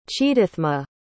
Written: chidathma

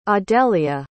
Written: audelia

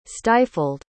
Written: stifled

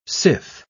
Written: sith

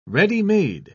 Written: ready-made

